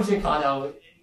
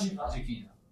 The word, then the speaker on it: Giacchino: It's Michael Giacchino, absolute legend. – You're just mispronouncing his last name. – It's not Giacchino. – Giacchino? – Yeah, Giacchino. – Michael Giacchino. All right.